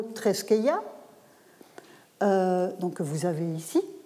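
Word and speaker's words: treskeia (θρησκεία): il va préciser que religio traduit le grec treskeia en prononciation érasmienne.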